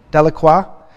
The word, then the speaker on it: Delacroix: Delacroix.